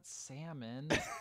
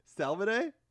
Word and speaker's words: salmone: No, not salmon. – Salmone? – Salmone is the Italian word for salmon. It's salmone.